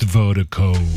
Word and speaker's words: Virdiko: And Vertigo is spelled V-I-R-D-I-K-O. That's V-I-R-D-I-K-O. Pronounced Vertigo.